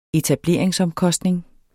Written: etablering

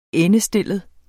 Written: endestillet

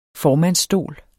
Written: formandsstol

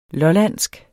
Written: lollandsk

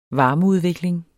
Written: varmeudvikling

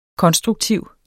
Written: konstruktiv

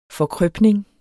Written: forkrøpning

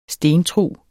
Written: stentrug

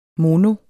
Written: mono

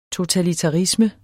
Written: totalitarisme

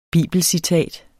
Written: bibelcitat

